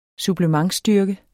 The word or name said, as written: supplementsstyrke